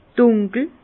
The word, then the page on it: dùnkel: Bas-Rhin; Herrlisheim